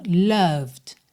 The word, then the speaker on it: loved: Cuidado para não falar loved, tá? Nem o O é O e nem o E é pronunciado. Então, loved, loved.